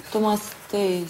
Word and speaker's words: tomasteis: И куда это ударение у нас? Томастей.